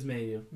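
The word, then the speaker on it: Meijer: I think it's pronounced Mayiger. – It's M-E-I-J-E-R. – It's Mayu.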